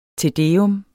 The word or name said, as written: tedeum